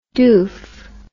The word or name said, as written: doeff